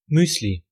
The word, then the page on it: céréale: France; [se.ʁe.al]